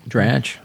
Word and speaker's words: Dratch: Dratch, how do you say? – Dratch.